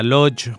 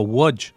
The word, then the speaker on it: Łódź: En la entrega anterior habíamos empezado a hablar de una ciudad importantísima llamada Lodz, o pronunciado en polaco Łódź,